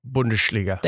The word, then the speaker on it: Bundesliga: It's pronounced Bundesliga.